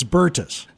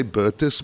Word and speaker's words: Bertus: Bertus Celia, yes. – So it's Bertus. – Yeah. Americans say Bertus, but it's Bertus in my language.